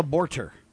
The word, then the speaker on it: Borter: Her name is Gabriela Borter. Now, I know it's not spelled the same, okay? – Pretty close to border. – B-O-R-T-E-R. Borter is her last name.